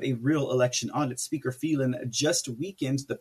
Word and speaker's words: Phelan: This here statement from President Trump addresses a very specific member of the Texas House, and that is Speaker Dade Phelan, or Phelan. Sorry, I pronounce it like an Hispanic.